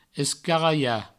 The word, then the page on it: escaralhar: escaraia; provençal